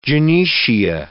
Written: gynoecia

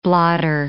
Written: blotter

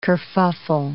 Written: kerfuffle

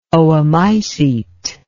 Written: oomycete

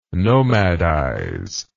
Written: nomadize

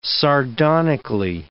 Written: sardonically

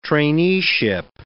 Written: traineeship

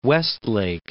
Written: Westlake

